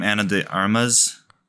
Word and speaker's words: Ana de Armas: Ana de Armas? – Yep.